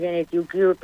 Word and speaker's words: Gene2Cure: Sí, en inglés Gene2Cure, pero sí, vale. Lo has dicho perfectamente.